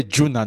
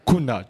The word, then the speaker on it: Gunnar: So, we're going to discuss about Ole Gunnar, not Kuna, Juna. So, the pronunciation was a bit wrong there.